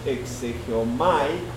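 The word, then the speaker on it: exegeomai (ἐξηγέομαι): Probablemente no sea una palabra que sea de nuestro diario hablar, pero esa es la palabra correcta, exégesis, que viene de una palabra en griego que se pronunciaría más o menos exegeomai, que significa explicar.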